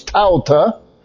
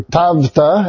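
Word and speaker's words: Tavta (ταῦτα): And so these things, Tauta, or Tavta, as it's pronounced today, says basically there's a transition point.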